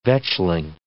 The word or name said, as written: vetchling